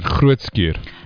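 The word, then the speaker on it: Groote Schuur: So they raise funds to reduce the waiting time for surgery by covering costs for additional theatre time and nursing staff at Groetskuur. Did I say that right? – You did Groetskuur.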